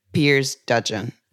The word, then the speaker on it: Piers Dudgeon: Mr. Piers Dudgeon, and I hope I pronounced it correctly. Hello, Piers, and thank you for accepting our invitation. – Hi. Yes, you did well. You did very well.